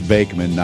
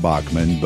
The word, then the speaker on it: Bachman: Randy, by the way, we've mispronounced his name our whole lives. It's Bakeman, not Bachman, but he doesn't argue because he made a lot of money of us from us buying his records over the years.